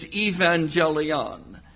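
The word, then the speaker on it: evangelion (εὐαγγέλιον): That word is translated gospel, and the original Greek word is, in my crude pronunciation of the Greek, is evangelion.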